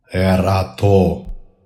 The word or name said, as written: ERATO